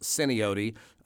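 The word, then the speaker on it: Atsena Otie: And I'm saying it incorrectly. For the locals will say it's pronounced Sinaote. Atsinaote is actually just a made-up word. It's a cobbled together of several Muskogee and Indian words. So I should be calling it Sinaote, but your listeners probably know it as Atsinaote.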